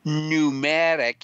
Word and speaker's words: Pneumatic: It looks like the slight favorite right now is Pneumatic, even though that starts with a P. Am I saying that correct, Dr. Wing? – Very well pronounced.